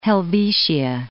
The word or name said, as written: Helvetia